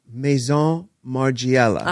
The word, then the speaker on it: Maison Margiela: I got to say, you know, I recently saw the fall collection of Maison Margiela.